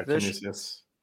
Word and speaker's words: Canisius: Canisius? Is that what it's called? – Yeah, Canisius.